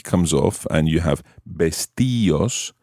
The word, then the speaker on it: vestíos: However, you have to add an accent to the I in order to keep that type of pronunciation. Vestíos, vestíos. This is because the combination of I-O normally is pronounced as one syllable. Think of the word Dios, Dios. It's not Dios, but Dios. So vestíos requires an accent.